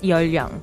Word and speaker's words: 연령: 연령. It's actually spelled 연 Ryung, but it's pronounced Yeolyung there.